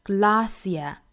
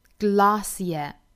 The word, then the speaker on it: glacier: 英音的读法呢是 Glacier, glacier, 美音读法是 glacier, glacier, 不光是元音、后面的这个辅音也是不一样发音的、两处不同，这个单词我们要注意。